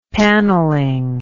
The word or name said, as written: paneling